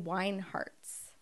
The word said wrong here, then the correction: Weingarts: Did I say that right? – I think it's Weingarts. – Weingarts, yeah.